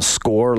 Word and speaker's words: Score: It's Adam Score, like a goal with a big G, so that that's how my name gets pronounced, right?